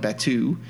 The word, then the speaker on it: Batuu: Batuu.